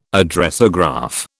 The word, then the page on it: Addressograph: British English